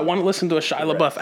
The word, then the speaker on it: Shia LaBeouf: And it's pronounced Shia LaBeouf.